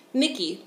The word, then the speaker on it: Nikki: Nikki, with double K.